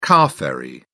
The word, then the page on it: car-ferry: British English